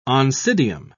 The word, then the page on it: oncidium: British English